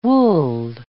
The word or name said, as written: wooled